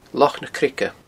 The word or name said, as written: Loch na Creige